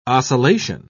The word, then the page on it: ocellation: British English